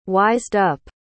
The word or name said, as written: wised-up